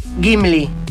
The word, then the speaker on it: Gimli: Gimli, con G-I, sin U.